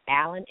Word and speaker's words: Allen: My name is spelled M-A-R-S-E-I-L-L-E, Allen, A-L-L-E-N, and Periscope.